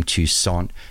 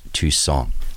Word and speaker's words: Toussaint: And in case you were wondering, I'm pronouncing his name Toussaint because that's the way he pronounced it. It's spelt T-O-U-S-S-A-I-N-T, but because of the French influence in New Orleans, it's pronounced Toussaint.